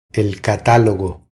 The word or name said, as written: El catalogo